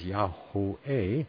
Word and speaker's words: Yahweh: The Heavenly Father's name pronounced as Yahweh meets all the laws of Hebrew pronunciation set forth by Young in his concordance.